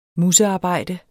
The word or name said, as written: musearbejde